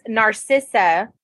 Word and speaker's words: Narcissa: I loved the end of, I actually loved that in the end, it was Narcissa.